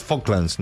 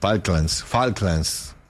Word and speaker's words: Falklands: Falklands. – No, la L no se pronuncia. – Falklands.